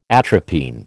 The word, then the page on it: atropine: American English